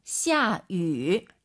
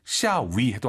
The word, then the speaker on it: 下雨: 샤유. – 입술을 동그랗게 말아서 위 하려고 하세요. 샤유. 샤유. 네, 이렇게 발음하셔야 됩니다. 절대 위, 입이 가로로 찢어지면 안 됩니다, 여러분. 물론 샤위 해도 알아듣지만 말이죠.